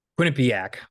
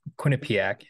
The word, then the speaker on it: Quinnipiac: Did I say that right? – Quinnipiac. Quinnipiac, yeah.